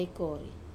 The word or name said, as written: kore